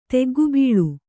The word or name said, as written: ತಗ್ಗುಬೀಳು